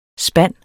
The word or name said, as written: SPANDES